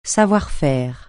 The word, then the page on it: savoir-faire: francais